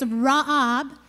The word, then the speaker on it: ra'ab: In the Old Testament of the Bible, famine was a Greek word, I'm going to pronounce it for you, it's ra'ab, meaning hunger.